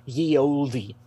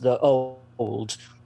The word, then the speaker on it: ye olde: Y-E-O-L-D-E, and they'll think that's how it's pronounced, ye olde. It's not. It's pronounced the old, because that's how they spoke, but they had different lettering, and as a result, they've changed over time, not that part of the language. So you'd still say, oh, it's the old pub rather than ye olde pub.